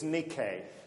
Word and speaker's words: Nike: Neither. It's Nike, okay, which is the Greek word for victory.